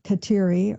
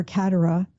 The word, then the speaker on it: Kateri: But eventually there would be priests that would come in, help form her in the faith, and she would be baptized Kateri, or Katera, as some pronounce it, named after the great Saint Catherine of Siena.